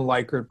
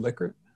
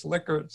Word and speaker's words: Likert: And by the way, I used to call them Likert, but I learned that it's called Likert. Somebody told me once, hey, you're pronouncing it wrong. So I went around, did some research and found out that the daughter of Likert said it's Likert.